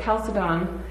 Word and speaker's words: Chalcedon: Then at the Council of Chalcedon, you can also pronounce it Chalcedon, in 451, two natures were affirmed to be present in Jesus.